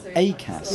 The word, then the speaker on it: Akass: Akass.